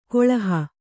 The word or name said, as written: ಗಳುಹು